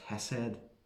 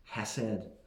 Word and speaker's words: hesed: And this loving kindness, in the original, in the Hebrew, is a little word pronounced hesed. Well, I think it's pronounced something like that, hesed.